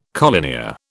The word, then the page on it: collinear: British English